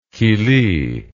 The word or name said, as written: killy